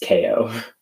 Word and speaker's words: KAO: I said KAO. I think it's just KO.